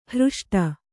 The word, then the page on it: ಹೃಷ್ಟ: hřṣṭa